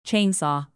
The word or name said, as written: chainsaw